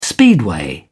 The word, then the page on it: speedway: British English